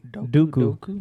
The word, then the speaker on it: Dooku: How do you say Dooku? – Count Dooku. Dooku. Not Sudoku.